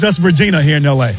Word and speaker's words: Regina: It's Regina. That's what? – The way it's pronounced.